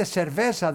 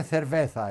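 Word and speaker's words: cerveza: Δεν έχουμε το θήτα. – Α, δεν έχετε. Λέτε σερβέσα, δεν λέγεται θερβέθα, έτσι? – Ακριβώς. Ναι. Σερβέσα με σίγμα, ναι.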